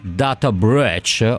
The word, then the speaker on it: data breach: So che venerdì ti sei lungamente occupata, insieme all'amico Giardina peraltro, di questa vicenda di Homobile, che è stata funestata da un data breach, o come si dice, ce l'abbiamo il nostro ascoltatore, professore di pronuncia? – Data breach. – Ecco, grazie, così.